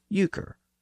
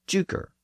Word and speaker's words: euchre: It was the name of the highest card in the game of euchre, and some scholars think the name of the joker was actually derived from a common mispronunciation of the game of euchre. Some people referred to euchre as juker, and the highest card in the game was sometimes called the juker card.